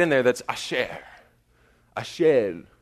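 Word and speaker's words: asher (אשר): I listened to its pronunciation like 12 times last night, and my wife turned to me and thought I had gone crazy because I was just sitting there listening to a woman say asher over and over and over again. But by God, that's how you pronounce it, according to some random user from Israel, right? But she says, it's Asher, Asher.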